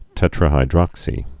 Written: tetrahydroxy

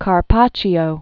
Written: Carpaccio, Vittore

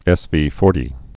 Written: SV40

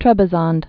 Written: Trebizond

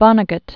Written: Vonnegut

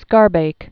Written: Schaerbeek

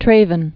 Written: Traven

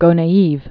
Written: Gonaives

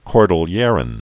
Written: cordilleran

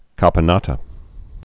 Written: caponata

